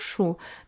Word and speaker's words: Шу: Вот, кстати, перед этим еще хотела сказать, вот я же с Чу, у нас в Жамбылской области... – Шу, правильно говорить. – Ну да, Шу, да, это раньше так Чу говорили.